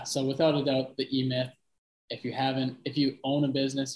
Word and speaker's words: E-Myth: I think last episode, when I just re-listened to that, you mentioned the E-Myth Revisited, or E-Myth book. Am I saying that right? – Yep. Yeah. It's the e-myth. So it's the entrepreneurial myth.